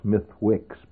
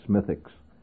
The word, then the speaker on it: Smithwick's: But what you generally see in the pubs, of course, you find Guinness Stout being drunk, Smithwick's, actually spelled Smithwick's, but pronounced Smithwick's, which is a very, I think, an evil drink.